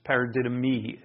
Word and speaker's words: paradidomi: 14 times paradidomai is used. Paradidomi, it should be pronounced.